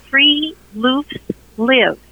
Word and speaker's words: friluftsliv: but it's called Free Loofs Live. – Free Loofs Live. What's that? – That's right. In Norwegian, that's spelled FRILUFTSLIV. It's three separate words.